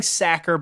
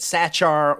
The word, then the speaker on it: Sachar: So the book, as we stated, was written by Lewis Sacker, and I'm saying Sacker because I had to look it up because I distinctly remember no one being able to pronounce this son of a bitch's name right. It was people said Sachar or Sakar. I think it's Sacker from what I understand. I'm going to call him Sacker if I'm wrong.